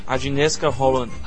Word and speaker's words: Agnieszka Holland: Agnieszka Holland.